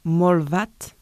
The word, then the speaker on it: Målvakt: Målvakt. – Avec le son KT qui est très léger à la fin du mot.